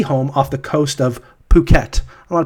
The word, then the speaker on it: Phuket: So in Thai, PH is always pronounced puh, except for pho, which is very confusing. But yeah. But that, yes, this is what I found. Phuket.